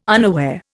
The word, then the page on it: unaware: Indian